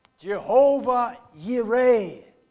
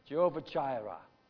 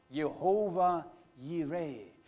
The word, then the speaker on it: Jehovah Jireh: Now the Bible says Jehovah Yireh. That's the real Hebrew expression. We have anglicized it and say Jehovah Jireh. No, it is being pronounced Jehovah Yireh.